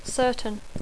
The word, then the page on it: certain: English